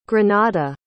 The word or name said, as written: granada